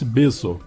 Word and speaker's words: beso: Um beijo para Mafalda e um berro para Liara. – Que tal o espanhol de Valdir Luiz? Só que é um beso. Em espanhol pronuncia-se beso.